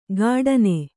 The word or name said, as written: ಘಟನೆ